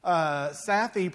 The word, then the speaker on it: Safi: Safi, pronounced like taffy with an S.